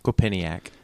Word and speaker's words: Quinnipiac: President Obama has ranked number 1 in a new poll from Kennepack Quinnipiac? How do you say that? – Quinnipiac. – Thank you.